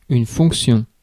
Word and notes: fonction: [fɔ̃k.sjɔ̃]

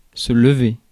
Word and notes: weigh: /weɪ/; [weɪ]

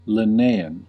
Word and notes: Linnaean: American English; /lɪˈneɪ.ən/; /lɪˈniː.ən/

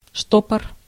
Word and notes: штопор: /ˈʂtopər/

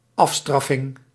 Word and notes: punishment: American English; ['pʌn.ɪʃ.mənt]

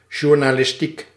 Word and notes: journalistiek: /juɾ.na.lɪs.ti:k/

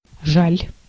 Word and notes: désolé: /de.zɔ.le/

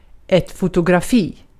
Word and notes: photograph: /ˈfəʊtəɡɹɑːf/; /ˈfəʊtəɡɹæf/

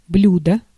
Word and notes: блюдо: [bʎʲu.dɒ]; /ˈblʲʉdə/; bljudo

